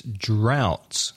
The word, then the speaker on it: drought: And a word I think we had recently in a recent podcast, sequía, which in English is drought. – Ooh, that's a hard one to pronounce. – D-R-O-U-G-H-T. The vowel sound is ow, like now or how. And the pronunciation, drought.